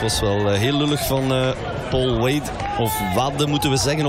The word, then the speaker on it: Paul Wade: Dus dan zullen we het maar op zijn Frans uitspreken. Omdat het, zoals het geschreven is, W-A-D-E. Neigt om Engels uitgesproken te worden. Maar mijn Franse collega's geven aan dat het wel degelijk op zijn Frans moet uitgesproken worden.